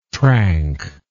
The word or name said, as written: trank